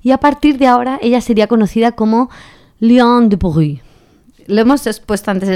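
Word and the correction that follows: Liane de Pougy: Liane de Pogui, para los que quieran googlearla, ¿vale?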